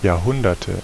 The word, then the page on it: Jahrhunderte: /jaːɐ̯ˈhʊndɐtə/